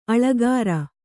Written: ಆಳಿಕಾರ

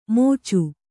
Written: ಮೋಚು

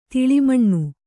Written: ತಾಲಮಾನ

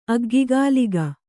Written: ಅಗ್ಗಿಗಾಲಿಗ

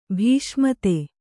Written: ಭೀಷ್ಮತೆ

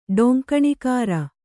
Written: ಟಂಕಣಕಾರ

